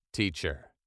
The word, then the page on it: teacher: British English